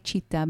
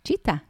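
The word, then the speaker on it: צ'יטה (चित्त): המילה היא צ'יטה בסנסקריט. צ'יטה? צ'יטה. צ'יטה, כמו הנמר. כמו הנמר, אבל כתוב אחרת. – אוקיי. Okay. – C-I-T-T-A, צ'יטה, ככה מבטאים את זה.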